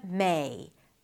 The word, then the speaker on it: May: Start with an E, then shift to an E, not my, but May.